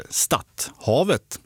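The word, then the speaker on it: Stadhavet: Och Lennart i Västra Ämtervik, vi kan ha bättre koll än vad jag har på hur norska hav ska uttalas. Stadthavet, så ska det vara.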